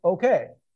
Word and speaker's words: OKEH: That says it's pronounced okay, OKEH, and it meant it is so, or it is indeed.